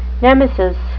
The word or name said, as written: nemesis